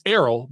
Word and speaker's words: Errol: It's Errol, by the way. It's Errol Spence Jr., but I'm just going to call him Earl because that's what everyone else seems to say.